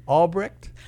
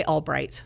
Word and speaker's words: Albrecht: Albrecht? – It's funny. That's how it's spelled, but generally people say Albright. The way the family pronounced it.